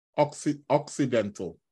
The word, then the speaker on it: occidental: Just after a new moon, is the moon oriental or accidental? Occidental, let me pronounce it correctly.